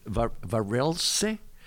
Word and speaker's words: Varelse: The word is Varelse. But I think the pronunciation is Varels.